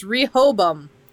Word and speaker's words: Rehoboam: It's Rehoboam is apparently how you Rehoboam. – Pronounce it.